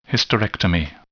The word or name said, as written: hysterectomy